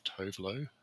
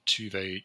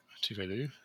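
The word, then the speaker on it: Tove Lo: I always thought it was Tovelo. And then when she was on RuPaul's Drag Race and they pronounced it Tuvelu. And I'm like, I never knew that that's how it was pronounced.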